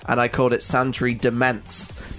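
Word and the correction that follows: Demesne: That wasn't great. It's Santry Domain. The S is silent.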